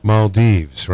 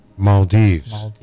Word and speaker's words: Maldives: Do you say the Maldives or the... – Maldives. – Maldives, right? Yeah. Maldives. That's it. That's what it is.